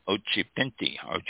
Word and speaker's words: Occhipinti: This is Ariana Ochipintle. I think that's how it's pronounced. O-C-C-H-I-P-I-N-T-I. Ochipinti.